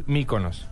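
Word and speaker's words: Mykonos: ¿Cómo es la pronunciación real? no, es muy griega, pero nosotros le decimos Miconos, está bien así. – Ah, está bien decir Miconos.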